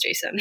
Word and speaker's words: Jacin: I think I said Jacin at first too, but then like I found this pronunciation thing online that was like a guide to how to pronounce all the names, and it was Jacin.